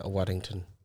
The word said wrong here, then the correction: Waddingham: Waddingham? Waddingham. Waddingham. Yeah.